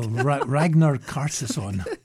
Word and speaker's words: Ragnar Kjartansson: There's a double pass to the National Gallery of Victoria's Ranyer Kjartansson. – Pronunciation's right next to the word. – Oh, Ragnar Kjartansson.